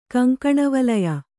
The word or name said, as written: ಕಂಕಣವಲಯ